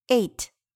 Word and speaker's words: ate: 好，那么 ate 这个词是 eat，它的过去式和八 ate 它的发音是一样的。